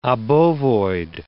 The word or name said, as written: obovoid